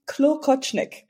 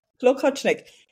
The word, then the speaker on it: Klokotschnik: Klokotschnik. It's actually Klokotschnik. – Klokotschnik.